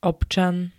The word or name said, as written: občan